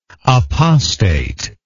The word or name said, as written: apostatically